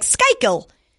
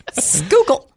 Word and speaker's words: Schuylkill: It's like S-C-H-U-Y-K-I-L-L, I think. – Oh, okay. I've seen that before, that word, and I thought it was like Skykill. – No, it's Schuylkill. I just don't know. Scooby Bond movie, Skykill. – Schuylkill.